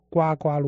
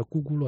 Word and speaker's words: 呱呱: 我举个简单的例子，很多人他都会把这个孩子出生啊叫呱呱落地，对不对？或者其实正确发音很早之前叫咕咕落地，是吧？ – 真的呀。我不知道，回去查一下，没有人读呱呱的，但是后来大家都错的，都读呱呱了，都无所谓了嘛。